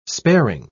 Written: sparing

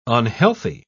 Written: unhealthy

